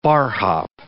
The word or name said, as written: barhop